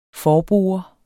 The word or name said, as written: forbore